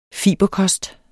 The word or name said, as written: fiberkost